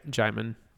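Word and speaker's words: Djimon: it's definitely which by the way Jaimon Jaimon uh, Hounsou plays uh, the wizard um, Is that how you she, pronounce his name? – Yeah, Jaimon. Jaimon.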